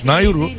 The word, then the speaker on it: Nauru: Pasifik ada ülkesi Nauru Cumhuriyeti. Nauru. Nauru'ymuş, öyle okunuyormuş. Nauru.